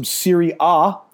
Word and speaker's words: Serie A: Since it is the morning and we're going to have this up, you have some Serie A. That's how you pronounce it.